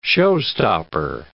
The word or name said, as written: showstopper